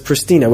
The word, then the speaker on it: Pristina: I mean, I read English maps and such, and it says Pristina with an S. I understand the pronunciation in this country is Pristina. But then I see, for example, in publications, that people write SH.